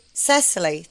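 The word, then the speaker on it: Cecilie: including one with Audrey Cordon-Rigaud and Silla Utrecht Ludwig. Anyway, that was very threatening, that one. I've forgotten how to say it. Cecily Utrecht Ludwig. – Just say Cecily. Think of say.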